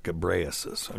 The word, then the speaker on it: Ghebreyesus: Try to pronounce this: G H E B R E Y E S U S. Gabraisis. Gabraisis. Okay, Gabraisis. I'll say that.